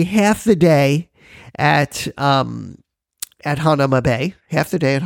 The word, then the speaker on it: Hanauma: Now, Hanauma Bay, or Hanauma Bay, however you want to say it, Hanauma Bay is the correct pronunciation.